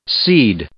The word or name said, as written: seed